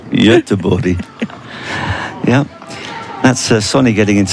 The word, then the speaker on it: Göteborg: Well, in English, the version is Gothenburg. But Göteborg, obviously, is how you say it in Swedish, but I gather that that's not the correct way to pronounce it. – No, it is not.